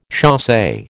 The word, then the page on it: chasse: American English